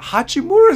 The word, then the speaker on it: Hachimura: Hachimura is his name. – Come on, Hachimura.